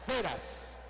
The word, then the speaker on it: Firas: Se llama Feras. – Firas al Burakian. – Pero se escribe Firas, ¿no? – Sí. Firas.